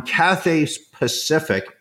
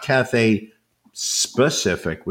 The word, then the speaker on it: Cathay Pacific: It is, you feel like you have entered purgatory that will last forever, but we were on Cathay Pacific, not Cathay Specific, which, you know, was, you know, the way I pronounced it the first couple of times.